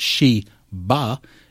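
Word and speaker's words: Shi Ba (十八): In Chinese tradition, 18, pronounced Shi Ba,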